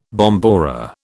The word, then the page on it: bombora: British English